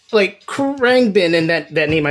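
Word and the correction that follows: Khruangbin: It's pronounced like Krungbin.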